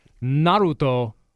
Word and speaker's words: Naruto: How do you pronounce that, the manga thing? – Oh, yeah, the ninja thing that the guy was trying to copy. Line one said it's Naruto, and he's sorry he knows that.